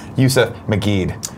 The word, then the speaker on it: Youssef Maguid: Yousef Magid.